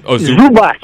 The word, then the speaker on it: Zubac: Ball, Kuzma, Ingram, the center, how do you say his name? – Zubac. Oh, Zubac. I thought it was with a V.